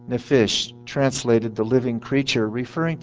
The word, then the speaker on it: nephesh: This is the Hebrew word nephesh. I'll spell it for you. N-E-P-H-E-S-H. Nephesh.